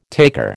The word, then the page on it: taker: American English